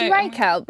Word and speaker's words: Reichelt: I think it's Reichelt. The third Reich is spelt like that.